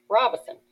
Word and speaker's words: Robeson: I believe she wanted everybody to pronounce it phonetically correct, like most of the folks that don't live here say Robeson, which is probably phonetically correct, but Colonel Robeson wanted it pronounced Robeson.